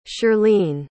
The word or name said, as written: shirleen